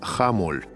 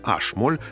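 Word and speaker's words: h-Moll: Соответственно, это была буква H, которую академические музыканты во всем мире, кроме Англии и Америки, традиционно произносят на немецкий манер, как ха. Таким образом, тональность, например, знаменитой высокой мессы Баха или третьей сонаты Шопена, по-русски звучащая как си минор, на языке международного общения академических музыкантов называется хамоль а вовсе не ашмоль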